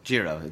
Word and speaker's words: Jiro: It's this Hero guy or Jiro? How do you say it? – Jiro.